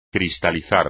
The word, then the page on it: cristalizar: espagnol